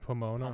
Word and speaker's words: Pomona: It's like Pomona. – Pomona. – Pomona. – Pomona. – Okay, I just can't see the small text from here. – P-O-M-O-N-A. – Okay, so Pomona.